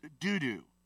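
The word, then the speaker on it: Dodo: And after Abimelech, there arose to save Israel, Tola, the son of Puah, the son of, and it's literally pronounced doo-doo.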